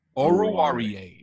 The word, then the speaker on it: Oruwariye: Oruwariye is how you pronounce it.